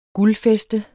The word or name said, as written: guldfæste